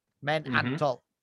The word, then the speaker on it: Men An Toll: This one is called Men An Toll. – Men An Toll. Okay. – It's like how you say it. Men An Toll.